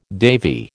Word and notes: Davy: American English

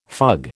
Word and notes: fug: American English

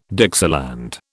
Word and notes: Dixieland: British English

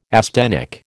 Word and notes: asthenic: American English

